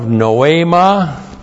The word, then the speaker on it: noema: And then we have noema. N-O long E-M-A. Noema.